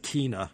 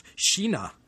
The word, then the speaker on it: Kina: I said Kina in an earlier podcast, and it should be pronounced Sheena. Who knew a Swedish K is our English SH?